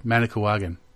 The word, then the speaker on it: Manicouagan: 120 miles or 193 kilometers is the what Manicouagan or no? – Wow. – Yeah, I think I said it. Did I say it right this time? – Manicouagan.